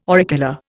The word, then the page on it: auricular: Indian